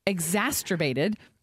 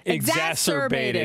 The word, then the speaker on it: exacerbated: This is a hard year where if you're going through whatever you're going through, it's going to be exacerbated. Did I say that right? – No, almost. – How do I say it? – I can't do it either. – Exacerbated. Exacerbated. – Yeah.